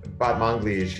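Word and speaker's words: Batmanglij: Rostam, I don't know if it's Rostam or Rostam, and it's Batman Gleesh? – Batman Gleesh, yeah, Rostam Batman Gleesh.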